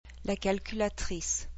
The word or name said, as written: la calculatrice